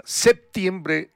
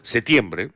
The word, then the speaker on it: septiembre: Igual, aquí en el Perú generalmente no pronunciamos mucho la P, septiembre, a secas, así. Igual, está bien pronunciado. En otros países se le da la acentuación en la P, septiembre. Pero igual suena, ¿no?